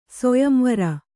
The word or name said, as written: ಸೊಯಂವರ